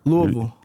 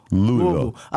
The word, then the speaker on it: Louisville: Am I saying it wrong? Louisville? – Louisville. A lot of people say Louisville, you feel me? – Saying Louis is kind of trashy.